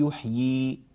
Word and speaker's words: يحيي: فنحن نقرأ في الوصل يحيي الأرض، فالياء مكسورة، طبعا الياء الثانية لا تظهر تخلصا من التقاء الساكنين، لكننا إذا وقفنا نثبت الياءين فنقول يحيي، يحيي هكذا.